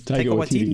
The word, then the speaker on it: Taika Waititi: Taika Waititi? – Taika Waititi, yeah. – Oh, okay. Taika Waititi.